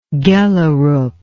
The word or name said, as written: gjellerup